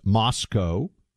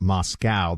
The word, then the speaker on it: Moscow: We've also been told by people from the region that we're supposed to pronounce this Moscow. So we have learned, not like the way Americans pronounce Moscow, the city in Russia.